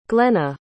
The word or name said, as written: glenna